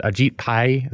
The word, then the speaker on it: Ajit Pai: Is it Ajit Pai? Is that how it's pronounced? – It's close. Yep. Yep. – Yeah, pretty close.